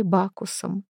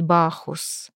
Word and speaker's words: Бахус: К тому времени у меня остался только один компаньон, гигант-негр, бывший на самом деле моим похитителем и тюремщиком, которого все называли Бакусом». Она произнесла имя с подчеркнутой внятностью. «Потому что никто там не знал, что правильно говорить Бахус».